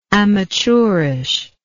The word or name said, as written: amateurishness